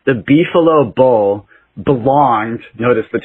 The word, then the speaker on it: beefalo: So a beefalo, in case people don't know, I didn't mispronounce that. It's actually a beefalo.